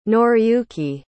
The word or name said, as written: Noriyuki